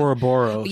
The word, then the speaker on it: Ouroboros: Ouroboros. – Yeah, thank you. Ouroboros like scenario.